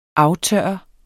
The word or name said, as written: aftørre